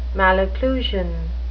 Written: malocclusion

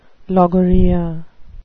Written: logorrhea

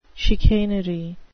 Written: chicanery

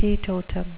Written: teetotum

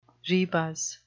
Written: rebus

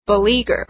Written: beleaguer

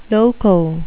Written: loco

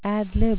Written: ad lib

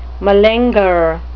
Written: malingerer

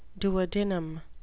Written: duodenum

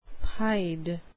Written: pied

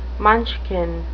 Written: munchkin

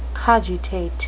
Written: cogitate

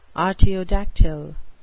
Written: artiodactyl

